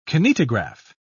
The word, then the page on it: kinetograph: British English